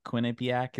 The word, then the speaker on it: Quinnipiac: And the first thing that popped up to me is Quinnipiac. Quinnipiac, is that how you say it? – Oh.